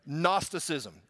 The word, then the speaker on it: Gnosticism: And there was the seeds of a heresy that was developing that is called Gnosticism. G-N-O, and you can spell it yourself later, but Gnosticism, okay?